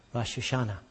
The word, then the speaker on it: Rosh Hashanah: Rosh Hashanah, is that right? Pretty close. Say it correctly for me. Rosh Hashanah. Okay, you got that.